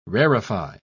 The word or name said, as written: rarefy